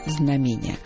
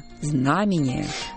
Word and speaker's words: знамение: Но вы нас нисколько не удивите, если скажете, что чаще слышали другое ударение – знамение. А вот почему так происходит, не очень понятно. Все, решительно все словари, начиная с «даля», ударение ставили и ставят исключительно на «а» – знамение.